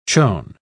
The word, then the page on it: chon: British English